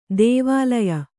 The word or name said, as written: ಧಾವಲ್ಯ